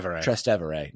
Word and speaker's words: Trastevere: So he had to get in his car and like drive towards like more like the Trevestri, neighborhood. I'm not saying that right either. – Trastevere neighborhood.